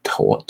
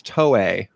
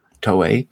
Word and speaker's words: Toei: How do you pronounce that? Towel? I think it's Toei. – Toei. All right. Also, the music was by Steve Rucker.